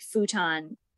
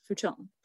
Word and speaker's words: futon: Like futon, for example. In Japan, it's pronounced futon. – Futon. – Yeah. – Mm-hmm. It's pronounced what? – Futon. – Oh. – Yeah. So futon. – Futon.